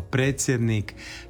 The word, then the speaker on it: predsjednik: Uostalom, znamo to i iz drugih primjera, pa riječ predsjednik, koja se piše sa D i S, čitamo kao predsjednik.